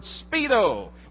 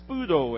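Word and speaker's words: spudo (σπεύδω): That little word, speedo, or we pronounce it spudo in the Greek.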